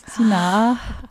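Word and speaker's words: Sinah: Sina.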